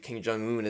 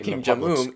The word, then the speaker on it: Kim Jong-un: You can believe Kim Jong-un is God if you want. But if you're going to set up a statue, right, to Kim Jong-un in the public square, to Kim Jong, I can't say his name.